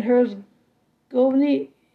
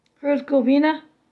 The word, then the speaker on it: Herzegovina: Bosnia and Herzegovina, Herzegovina, sorry for the mispronunciation, in 1995, U.S.